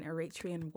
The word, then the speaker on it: Eritrean: So before we dig into kind of what led you to this place of being a woman of color and Eritrean woman.